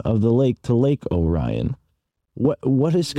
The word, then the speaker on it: Lake Orion: They pronounce it Lake Orion. It's spelled Lake Orion, but they pronounce it Lake Orion for whatever reason. I've always wondered why they don't pronounce it correctly, but it's Lake Orion.